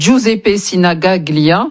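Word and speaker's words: Giuseppe Sinigaglia: C'est le stade Giuseppe Sinagaglia. J'espère que j'ai bien prononcé, Mathéo. – C'est bien. Mathéo, c'est bien. – C'est bien, c'est très bien.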